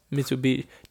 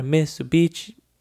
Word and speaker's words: Mitsubishi: Mitsubishi. Don't you wishy you had a Mitsubishi. I don't know how why I struggle to say that. It rhymes with wishy.